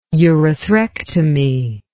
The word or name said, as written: urethrectomy